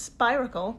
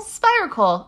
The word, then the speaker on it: spiracle: I think it's a spiracle. I think it rhymes with icicle. – Spiracle.